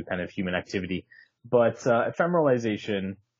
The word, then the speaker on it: ephemeralization: So the way you pronounce it is ephemeralization.